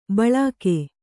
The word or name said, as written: ಭೀಲುಕ